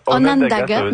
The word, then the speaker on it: Onondaga: Vos vedettes, ce sont le Empress of Ireland puis le sous-marin Onanga. Je ne sais pas si c'est comme ça. Est-ce que c'est comme ça qu'on le prononce? – Onondaga, merci.